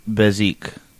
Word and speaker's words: bezique: Next we have is Bezique, which is a casino game. Am I butchering that pronunciation? – I think that's right.